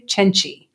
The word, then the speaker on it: Cenci: It's Beatrice Cenci. – Oh. – Because you got to ch your C's in Italian.